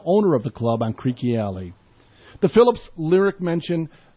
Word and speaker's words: Creque: The title of the song, which does not occur anywhere in the lyrics, is derived from Creaky, C-R-E-Q-U-E, or C-R-E-Q-U-I, pronounced Creaky Alley.